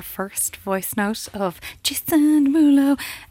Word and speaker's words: Derulo: Now we're giving away Jason Derulo tickets. – That's not how you say it, Melissa. – Oh, sorry. Jason Derulo.